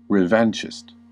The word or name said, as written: revanchist